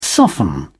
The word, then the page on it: soften: British English